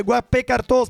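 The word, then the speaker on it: Guappecartò: Musica, ragazzi, come sempre nella giungla c'è una festa importante da fare, lo facciamo insieme a uno dei due Guappe Cartò, speriamo che si dica così. Marco, è giusta la pronuncia? – Hai detto perfettamente, complimenti, buongiorno.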